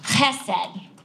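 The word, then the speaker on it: chesed (חסד): This word love in the Hebrew is pronounced chesed. You might even see it without the C sometimes, but it has that kind of guttural sound.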